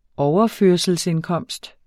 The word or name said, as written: overførselsindkomst